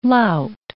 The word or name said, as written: lout